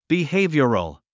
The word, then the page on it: behavioral: bihéivjərl; ビヘイビョラル